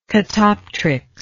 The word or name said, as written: catoptrics